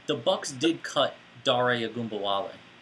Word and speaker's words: Ogunbowale: So, I know that you can pronounce his name because it's Dare Ogunbowale. So it's Dare Ogunbowale. It's easy, guys.